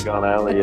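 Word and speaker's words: Diagon Alley: Is that how you say it? – Dagon Alley, yeah.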